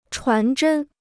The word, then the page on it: 传真: chuán zhēn